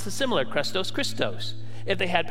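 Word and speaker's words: Christos (Χριστός): The word in Greek for kindness is krestos. If you spell it in English, it's C-H-R-E-S-T-O, krestos. And the word for Christ is Christos, C-H-R-I-S-T-O.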